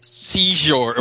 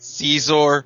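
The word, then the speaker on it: Xizor: Hey, Zizhor, it's knew? Pronounced Zizhor. – Oh, okay. – Prince Zizhor. That's pronounced Zizhor.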